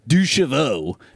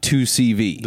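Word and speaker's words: Deux Chevaux: And he said, also, it's Duchevaux, not 2CV.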